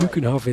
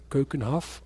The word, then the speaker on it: Keukenhof: Kuchenhof, it's spelled K-E-U-K-E-N-H-O-F, Kuchenhof. – K-E-U-K-E-N? – Yeah, H-O-F. H-O-F.